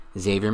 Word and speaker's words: Xavier: But again, Xavier McKinney, the X is not pronounced.